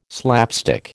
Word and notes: slapstick: American English

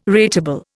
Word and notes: rateable: Indian

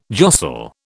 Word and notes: jostle: British English

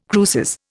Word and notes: cruces: Indian

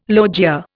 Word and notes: logia: Indian

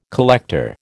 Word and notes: collector: American English

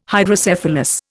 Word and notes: Hydrocephalus: Indian

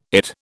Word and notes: IT: British English

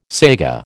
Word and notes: saiga: American English

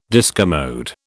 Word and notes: discommode: British English

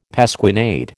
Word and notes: pasquinade: American English

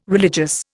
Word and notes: religious: Indian

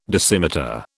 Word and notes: dosimeter: British English

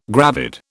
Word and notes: gravid: British English

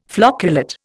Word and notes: flocculate: Indian

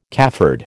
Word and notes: cafard: American English

